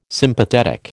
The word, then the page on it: Sympathetic: American English